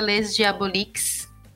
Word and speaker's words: Les Diaboliques: mas tem também na minha revista no Instagram, que é a revista Les Diaboliques. Escreve com S, mas pronuncia sem o S, francês. Pode enganar a gente.